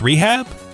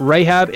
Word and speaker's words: Rahab: And it's not pronounced Rehab? – It's Rahab, isn't it? Like Rahab in the Bible? – I don't know.